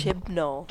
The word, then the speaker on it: Chibnall: Like, if Chinball goes, or what's Chinball? What, how do you say it? – Chibnall. – Chibnall.